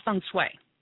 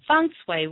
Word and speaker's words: feng shui: The first thing is feng shui, which is, it sounds like it doesn't, it's not spelled like it sounds. It's F-E-N-G-S-H-U-I, which is actually, it means feng, which means wind, and shui, which means water.